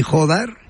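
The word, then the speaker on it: Jódar: Jodar, que también lleva acento en la O, Rafa, creo.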